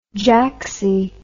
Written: jacksy

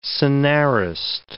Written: scenarist

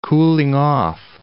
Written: cooling-off

